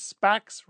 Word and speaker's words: SPACs: Hey, 10 years from now, 5-10 years from now, we'll add cryptocurrencies, special purpose acquisition corporations, SPACs, rhymes with smack, and NFTs to this list.